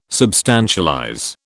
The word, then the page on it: substantialize: British English